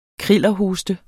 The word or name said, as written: krillerhoste